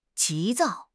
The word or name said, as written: 急躁